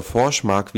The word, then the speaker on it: Forsmark: in Forsmark oder Forsmark, wie es wohl richtig ausgesprochen wird, nahezu an den Rand einer Katastrophe geführt.